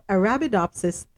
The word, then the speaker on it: Arabidopsis: If you know what watercress looks like, and let me see if I can break it down into syllables. Arabidop, Arabidopsis, Arabidopsis, Arabidopsis. There we go, Arabidopsis thaliana a type of cress,